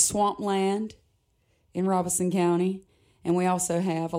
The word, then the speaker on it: Robeson: I am originally from Robeson County. And if you're from there, you know that that's how we pronounce it.